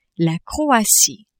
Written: Croatie

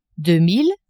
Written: deux mille

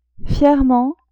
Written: fierement

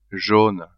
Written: jaune

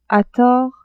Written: a tort

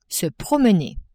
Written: se promener

